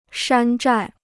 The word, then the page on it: 山寨: shān zhài